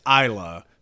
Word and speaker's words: Isla: Yeah, it's Ela. Exactly. It's Ela Fisher's character. – Ela? Isla? – Do we name her? – How do we pronounce it? – It's Isla.